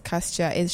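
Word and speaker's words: Cajsa: It's Kaistja. She's Swedish. It's like C-A-J-S-A, I think.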